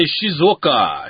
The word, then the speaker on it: Shizuoka: How do you say that? – I would say Shizuoka. Shizuoka. – Shizuoka. – Shizuoka, if you're speaking English, which I think is Jingang Xian.